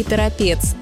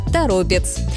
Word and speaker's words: Торопец: Хотя в новостях можно услышать и Торопец, но это ошибка. Объяснение здесь вполне прозрачное. Название города связано с рекой Торопа, от которой оно и образовано. Поэтому ударение сохраняется в корне слова – Торопец.